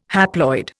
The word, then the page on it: haploid: Indian